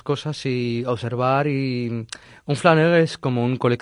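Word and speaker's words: flâneur: Escolta, ens pots explicar què és un flanen? No sé si ho pronuncio flaner. – Bé. Flaner? Sí.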